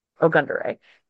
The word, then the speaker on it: Ogundere: And then she responded with, yes, it's, and then she explains phonetically how to say it, which is Ogundere. But yeah, if only.